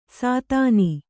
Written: ಸೀತೆನೆ

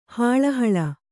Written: ಹಳಹಳಿ

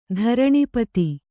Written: ಧರಣಿಪತಿ

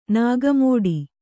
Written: ನಾಗಮೋಡಿ

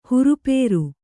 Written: ಹುರುಪೇರು

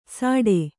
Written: ಸಾಟೆ

